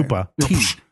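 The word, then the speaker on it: Koopa Tea: K-O-O-P-A. – Oh, Cooper. Okay. – Koopa. – T. – Obviously, I said Cooper, not Koopa, like Mario. – T-E-A.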